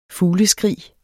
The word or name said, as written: fugleskrig